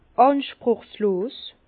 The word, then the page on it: ànspruchslos: Bas-Rhin; Herrlisheim